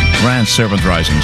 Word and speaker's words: Grand Serpent Rising: Grand Serpent Rising, zo zou je het moeten uitspreken.